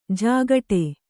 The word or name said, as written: ಝಾಗಟಿ